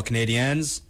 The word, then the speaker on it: Canadiens: And I think it's a perfectly good time to segue on over to the Boston Bruins, who face off against the Montreal Canadiens tonight.